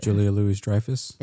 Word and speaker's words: Julia Louis-Dreyfus: Julia Louis-Dreyfus.